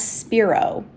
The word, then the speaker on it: Spiro: Most people read it as Spiro, but it's actually Spiro with a long I.